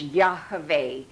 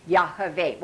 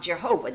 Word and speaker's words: Yahweh: It's the Hebrew Hebrew alphabet, Y-H-V-H. By the way, we studied the Hebrew alphabet on Wednesday night. Y-H-V-H is pronounced Yahweh. Want to say it? Yahweh. Okay, it's not Yahweh and it's not Jehovah.